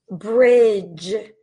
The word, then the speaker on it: bridge: And then here is another silent D sound. Bridge, bridge, bridge.